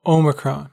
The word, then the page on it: omicron: American English